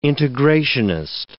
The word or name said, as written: integrationist